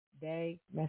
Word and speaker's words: de Mesones: It is pronounced Day Mesones. – Day Mesones.